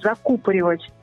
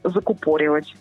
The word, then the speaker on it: закупоривать: Очень часто люди говорят вместо нормативного «пломбировать», «пломбировать», что неверно, а правильно говорить «закупоривать», а не «закупоривать».